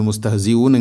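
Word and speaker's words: മുസ്തെഹ്സി ഊൻ (مستهزئون): മുസ്തഹ്സിഊൻ അല്ല മുസ്തഹ്, അതുപോലെ ഹ തൊണ്ടയുടെ താഴെ ഭാഗമാണ്. തൊണ്ടയുടെ താഴെ ഭാഗത്തു നിന്നും വരുമ്പോൾ അതിൻ്റെ ഉച്ചാരണം ഹ ഹി ഹു എഹ് മുസ്തെഹ് മുസ്തെഹ്സി ഊൻ.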